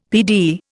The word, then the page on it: PD: Indian